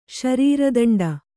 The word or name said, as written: ಶರೀರದಂಡ